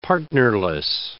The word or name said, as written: partnerless